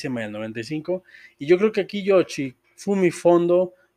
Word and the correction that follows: Kondo: Kondo, perdón, ya, ya lo dije mal, eh, se dejó guiar por Miyazaki.